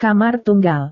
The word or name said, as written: kamar tunggal